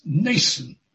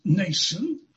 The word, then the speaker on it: Mnason: Well, never mind about that, but I rather suspect you don't pronounce the M in this name. The name is Mnason of Cyprus.